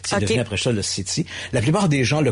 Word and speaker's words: SETI: Il nous reste encore un petit 2 minutes, et j'ai vu sur la fin qu'on a un don considérable pour SETI. C'est bien comme ça qu'on le prononce? – Oui, c'est ça.